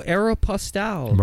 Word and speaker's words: Aéropostale: Oh, Aeropostale.